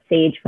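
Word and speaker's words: CAJE: We call it SAGE with a soft C in Miami, Florida.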